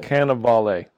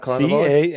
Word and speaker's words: Cannavale: Cannavale. – Is it? It's not Carnival.